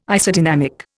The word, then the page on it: Isodynamic: Indian